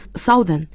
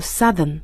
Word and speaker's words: Southern: 那么如果是南部的英文叫，这个就是比较怪异的，很多听众有可能写成或者是听成 Southern, 实际上它正确的发音是 Southern、 S-O-U-T-H-E-R-N, 很多人读成 Southern, 所以这个一定要注意是 Southern 这样的声音。那么还有一个就是西部的英文叫 Western, 比如我们西部电影就直接可以叫 Westerns。